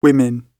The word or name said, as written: women